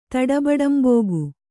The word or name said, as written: ತಡಬಡಂಬೋಗು